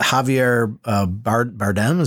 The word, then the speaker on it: Javier Bardem: I was laughing a lot at, is it Javier Bardem? Is that how you say his name? – Yeah.